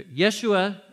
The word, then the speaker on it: Yeshua: In Aramaic or in Hebrew, the way you pronounce his name is either Yeshua as a short form, or Yehoshua is a long form where we get Joshua, Yeshua or Yehoshua.